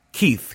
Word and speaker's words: Keith: My name is spelled first, Keith, K-E-I-T-H, family name, P-E-R-R, yes, like a double barrel, round as a barrel, yes, P-E-R-R-O-N.